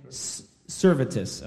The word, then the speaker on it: Servetus: Oh, we're going to start this with is it Servetus? Is that how you say his name? Servetus. Okay, I think that's right too, but I think you'll know who we're talking about when we get into him.